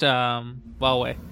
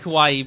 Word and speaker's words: Huawei: I think that's pronounced Huawei. – Huawei? – Yeah. I like Hawaii better, personally, but apparently... – Is it actually how... – Apparently that is what okay. – Official sources pronounce it as.